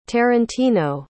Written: tarantino

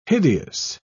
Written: hideous

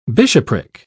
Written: bishopric

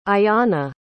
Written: ayana

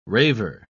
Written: raver

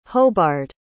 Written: hobard

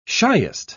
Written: shiest

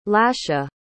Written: lasha

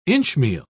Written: inchmeal

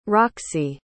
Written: roxy

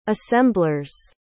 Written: assemblers

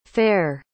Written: faire

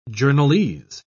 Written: journalese

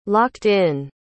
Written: locked-in

